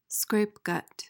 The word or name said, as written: scrapegut